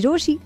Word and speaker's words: Rochie: मेरा नाम है मिर्ची रोशी, नाम की स्पेलिंग है ROCHIE।